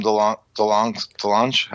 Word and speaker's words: DeLonge: And I think that was through Tom DeLonge.